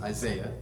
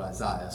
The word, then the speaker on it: Isaiah: The English way that we pronounce it is Isaiah. If you were wondering, everyone else on the planet calls it Isaiah, so just that's where it's coming from.